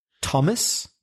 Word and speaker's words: Thomas: I'm talking about words like Thomas, that's T-H-O-M-A-S, or the River Thames, the famous river which goes through London. It's spelled T-H-A-M-E-S, and it's pronounced Thames.